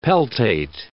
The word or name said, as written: peltate